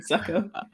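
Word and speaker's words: Succop: On the Tampa side of things, Suck Up, is that how you say his name? Is also another... – Yeah, right, Suck Up.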